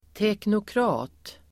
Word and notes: teknokrat: [teknokr'a:t]

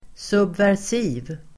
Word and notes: subversiv: [subvärs'i:v]

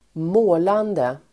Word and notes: målande: [²m'å:lande]

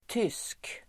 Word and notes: tysk: [tys:k]

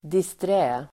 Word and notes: disträ: distr'ä:

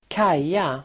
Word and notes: kaja: [²k'aj:a]

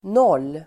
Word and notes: noll: nål:

